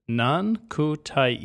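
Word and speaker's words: nankhatai: One guy said there's something called, he's even provided a pronunciation guide, which I really respect. It's Nan Kutai. He said, because you may or may not butcher it, and he was correct. I did, in fact, butcher it.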